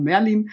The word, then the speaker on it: Merlin: Wir haben einen Chefbrauer, das ist Merlin, auf Deutsch ausgesprochen Merlin.